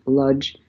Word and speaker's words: Łódź: In any case, when I was 5, I was taken to the zoo in Łódź, Poland. English readers will pronounce it as Ludge.